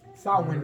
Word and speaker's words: Samhain: Samhain is the correct pronunciation.